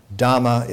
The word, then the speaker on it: Dhamma: Dhamma is the southern pronunciation of Dharma. In Ceylon, they speak with a southern accent.